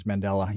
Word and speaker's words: Mandela: It's one of those mandala effect things. Yeah. – That's pronounced Mandala? Or I thought it was Mandela. – Mandela? – I think it's Mandela, yeah.